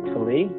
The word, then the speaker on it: Talie: As in, like, the way that I tell my students who are not familiar with my name is that my name is like pronounced like Toledo, but it's actually like a dough, so Talie is fine.